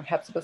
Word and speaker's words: Hepzibah: There's Hufflepuff's goblet, a cup that he gets from Hezepiah Smith, whatever her name is. I always say it wrong, Hepzibah Smith.